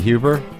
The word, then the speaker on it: Huber: Sophie Huber? – That's right.